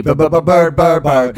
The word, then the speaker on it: Byrd: B-Y-R-D. – Bird.